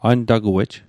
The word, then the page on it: antaguej: an'takuej; Mi'gmaq